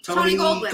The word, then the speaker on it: Tony Goldwyn: We just talked about Tony Goldwyn.